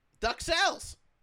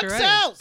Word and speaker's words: duxelles: Duxelles. – Duxelles. – Yeah, you're right. Duxelles.